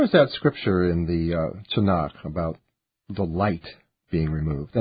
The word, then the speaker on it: Tanakh: And that said, that's an acronym, and you pronounce it Tanakh, but Christians call it and are familiar with it as the Old Testament.